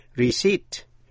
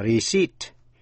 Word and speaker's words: receipt: Thí dụ như chữ R-E-C-E-I-P-T, receipt. Trong đó, âm P câm, không phát âm, đọc là receipt, chứ không đọc là receipt. Tên tiểu bang A-R-K-A-N-S-A-S, có âm S câm, đọc là Arkansas.